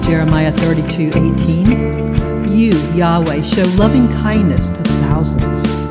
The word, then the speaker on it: Yahweh: God gives his name in Isaiah 42:8, YHWH, usually pronounced as Yahweh.